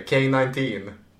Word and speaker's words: K-19: Så uttalas det, K-19.